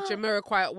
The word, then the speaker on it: Jamiroquai: Because I always say it gives me like Jamiroquai.